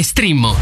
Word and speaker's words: Streammo: Vai su strimmo.it. Si scrive Streammo o scarica l'app.